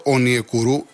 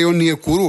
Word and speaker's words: Ονιεκούρου: Τον λέω Ονιεκούρου, εγώ δεν κάνω τον έξυπνο και δεν μου αρέσει ούτε να διαφέρω ούτε κλπ. Εγώ χρησιμοποιώ το όνομά του, ότι τονίζεται στη λίγουσα, γιατί το είπε ο ίδιος ο παίκτης. Εάν μπείτε μέσα και πατήσετε το βίντεο που μιλάει, οι πρώτες δηλώσεις, λέει Ονιεκούρου, ο ίδιος το λέει.